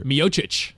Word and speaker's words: Miocic: Miocic.